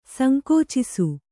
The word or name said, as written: ಸಂಕುಚಿಸು